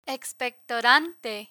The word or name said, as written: expectorante